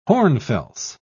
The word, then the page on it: hornfels: British English